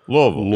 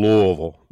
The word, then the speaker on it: Louisville: Say it properly. You have to go Louisville. – Louisville. Louisville. – Louisville. I said it right. The shocking thing, I did like a Jewish event like years ago, and just to hear Jews go, Louisville. I just went, no, come on, you're Jews. Louisville.